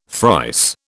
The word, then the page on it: thrice: British English